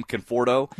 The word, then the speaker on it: Conforto: Michael Conforto, did I just butcher that name, Conforto? – You got it.